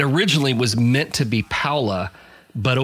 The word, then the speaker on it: Paola: I'd had some people tell me, and these were natives who told me that it was pronounced Paola.